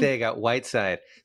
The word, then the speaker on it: Arcega-Whiteside: Arcega Whiteside.